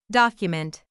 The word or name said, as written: document